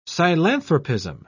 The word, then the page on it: psilanthropism: British English